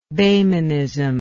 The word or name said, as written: Behmenism